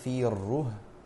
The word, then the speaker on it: فيره: وفي الره هكذا تلفظ بتشديد الراء المضمومة في الره وسكون الهاء